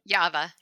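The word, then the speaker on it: Yava (Ява): Can you pronounce that factory? The Yava, right? – Yava, yeah,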